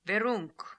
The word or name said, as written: Veroonk